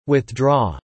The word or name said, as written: withdraw